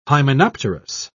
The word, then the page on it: hymenopterous: British English